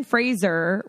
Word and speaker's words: Fraser: But Brendan Fraser, which I learned is how you say his last name.